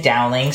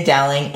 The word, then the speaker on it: Dowling: Yeah, so you can find me pretty much anywhere and everywhere that you go under the name Aiden Dowling. So A-Y-D-I-A. Wow, I spelled my own name wrong. So under the name Aiden Dowling, A-Y-D-I-A-N, Dowling, D-O-W-L-I-N-G.